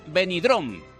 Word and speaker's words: Benidorm: Luz de Cruce en COPE, COPE Valencia, COPE Intercomarcas, COPE Alcoy, para toda la comunidad valenciana, incluida Benidorm. Y lo he dicho bien. Hola, ¿qué tal? ¿Cómo estás? – ¿Lo he dicho bien, Vicente? Lo has dicho perfectamente.